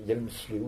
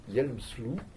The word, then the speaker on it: Hjelmslev: C'est un concept, nous en sommes redevables à un linguiste danois qui s'appelait Louis, alors ça s'écrit Hjelmslev, il paraît que les Danois prononcent Hjelmslev ou Hjelmslu.